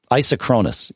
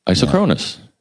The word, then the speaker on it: isochronous: Isochronous, is how it's supposed to be pronounced. – Isochronous.